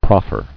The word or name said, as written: Proffering